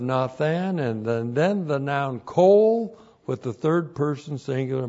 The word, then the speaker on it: kol: Q long O L, kol.